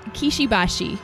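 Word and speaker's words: Kishi Bashi: Kashibushi. Wait, is that how you say it? – Kishibashi.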